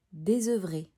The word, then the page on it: Désœuvrée: French